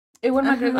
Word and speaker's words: Ewan McGregor: ¿Cómo se pronuncia? De que. Que nunca lo digo out loud, pero. Ewan McGregor. Ewan McGregor. Ah, ok, así se pronuncia.